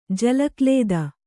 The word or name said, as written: ಜಲಕ್ಲೇದ